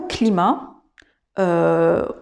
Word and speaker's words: climate: Par exemple, il y a le mot climat euh, français, donc c'est le mot français pour dire le mot climat en anglais qui va se prononcer climate, climate, avec la seule différence visuelle qui est le E à la fin du mot.